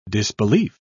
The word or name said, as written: disbelief